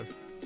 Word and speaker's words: Binder: Seguirà la milonga al Tango Sognato e poi nel bistrò invece il concerto dei Polemica e degli Hilary Binder, non so come si pronuncia. – Assolutamente comunque... – Binder. – Hilary Binder.